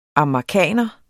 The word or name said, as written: amagerkaner